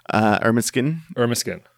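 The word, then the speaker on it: Ermineskin: Like, how do you improve how Ermiskin Cree Nation is run? – How do you say that? – Ermiskin.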